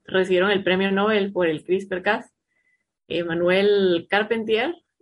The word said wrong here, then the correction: Charpentier: no sé si se pronuncia así, Charpentier. – ¿Tú sos la que sabe francés aquí? – Ah, ¿es francesa? – Sí, no sé. – Manuel Charpentier.